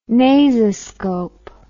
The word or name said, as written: nasoscope